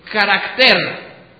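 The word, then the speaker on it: carácter (χαρακτήρ): La palabra griega para el carácter es, aunque escrito muy diferentemente, se pronuncia casi igual, es carácter. Que quiere decir cortar como en una inscripción, grabar, esculpir o tallar.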